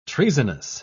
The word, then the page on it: treasonous: British English